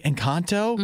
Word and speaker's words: Encanto: Why do I have to say it like that? – What do you mean? – That's how it's pronounced. It's the name of the movie. – Encanto. – You can also still say it that way. – Uh-huh. I guess I'm saying it with an American accent.